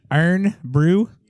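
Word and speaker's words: Irn-Bru: And how do you say it? – Really? – Earn brew. – Earn. – It's supposed like iron. the myth about it is that it was made.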